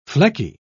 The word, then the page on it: flecky: British English